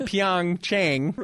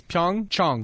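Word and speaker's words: Pyeongchang (평창): But it's not Pyeongchang. – Okay. – It's Pyeongchang. – Pyeongchang. – Someone who speaks Korean said, I can't listen to the Olympics because everyone pronounces it incorrectly.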